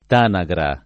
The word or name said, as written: Tanagra